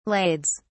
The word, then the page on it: lades: British English